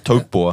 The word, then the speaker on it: Taupo: Ja, Taupo. Ja. – Taupo. – Taupo ist actually a Maori word. Taupo. Ja.